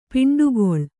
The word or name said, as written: ಪಿಂಡಗಲ್